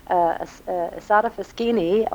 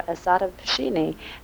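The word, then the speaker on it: Isotta Fraschini: Asata Fraschini or Asata Fraschini. Now, there were two pronunciations I had.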